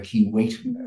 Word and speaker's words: Keewatin: Step three is that over the winter and in the spring, we'll be creating an interpretation exhibit space for the Keewatin, or the Keewatin, as we now pronounce it, excuse me.